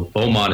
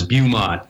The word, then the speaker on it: Beaumont: And they bring up maps and they're mispronouncing, you know, Beaumont is Beaumont, you know, so it's, you know, if you listen, you can hear, okay, you're probably not from here.